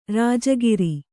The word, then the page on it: ರಾಜಗೀರೆ: rājagīre